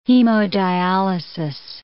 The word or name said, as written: hemodialysis